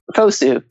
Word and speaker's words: FOSU: FOSU. – FOSU. Is that how you pronounce it? Okay, I was wondering.